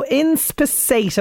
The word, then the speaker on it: inspissate: So inspissate, am I saying this right? I am. – Yes, you are.